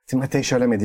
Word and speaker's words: Timothée Chalamet: Timothée Chalamet diye okunuyormuş ama çocuğun kendisi bile istediğiniz gibi okuyun falan diyor.